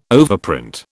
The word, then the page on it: overprint: British English